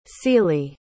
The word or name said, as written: sealey